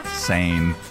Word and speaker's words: Seine: How do you pronounce it? It's the same. – Same. – Yeah. Shut up. Sane.